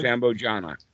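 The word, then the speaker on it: sambojjhaṅga: The Bojanka? Is this how you say this in Pali? – Sambojana. – Sambojana. Okay, so when we talk about the seven factors,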